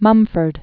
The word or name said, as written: Lewis Mumford